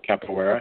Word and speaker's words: Capoeira: Capoeira, okay.